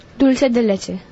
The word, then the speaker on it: dulce de leche: Dulce de leche.